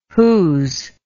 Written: whos

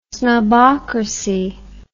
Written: snobocracy

